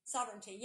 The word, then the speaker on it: sovereignty: Sovereignty. Yeah, there you go. Sovereignty. I said that wrong. Sovereignty, yes.